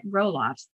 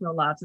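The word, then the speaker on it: Roelofs: They were, and I didn't realize that, Harriet Roloffs, or I think it's pronounced Roloffs, it's R-O-E-L-O-F-S, good German word, maybe Roloffs.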